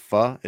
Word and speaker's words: pho: It's pronounced pho, is that what? – Yes.